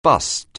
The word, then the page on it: bust: anglais